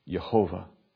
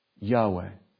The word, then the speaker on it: Yahweh: And so most scholars, as I say, do not believe that the way you pronounce the four consonants is Yehovah. We believe that it's pronounced Yahweh.